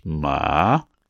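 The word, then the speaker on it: ma (马): For example, the character for horse, which in Mandarin is pronounced ma, is used to write the word horse, and in other characters, to represent the sound ma.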